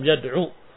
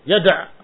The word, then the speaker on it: yad'u: Walam yad'u, baca yad'u, bacanya jangan yad'a, baca yad'u.